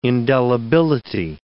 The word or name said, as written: indelibility